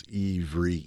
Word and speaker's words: Ivri: If you take the H off and just kind of pronounce Ivri, Hebrew, same, it's the same thing.